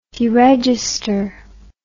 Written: deregister

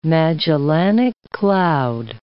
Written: Magellanic Cloud

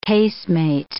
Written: casemate

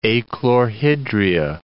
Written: achlorhydria

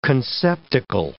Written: conceptacle

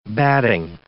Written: batting

